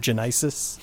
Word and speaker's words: Genisys: very spoiler happy John Carter in Genisys Transformers, not Transformers, Terminator Genisys.